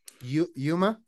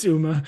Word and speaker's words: Uma: Yuma? Is that how you say it? – No, it's with a Y? Is that Uma. How you spell it? – It's Uma.